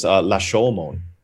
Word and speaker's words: Rashomon: My mom was correcting me. It's La Shomon.